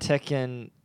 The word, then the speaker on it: Tekken: When people say Tekken, it sounds like chicken.